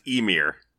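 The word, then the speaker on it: Ymir: Let's talk about a gentleman who is apparently pronounced, I think it's pronounced Emir.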